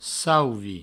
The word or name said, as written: sauvi